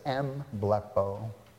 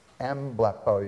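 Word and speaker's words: mblepo (ἐμβλέπω): Blepo is the root word, but in Luke 22:61 it says mblepo. Mblepo. You hear there's a syllable at the beginning? Blepo is to look. Mblepo means to look into.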